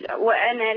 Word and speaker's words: وأنا: بيقولك إن الألف دي ما تنطقهاش في الوصل. وأنا لكم ناصح أمين تتقال إزاي؟ – وأنا لكم ناصح أمين.